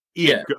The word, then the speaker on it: Eion: It's E-I-O-N, but it's Ian or Ian. – Ian. – I had it right the first time.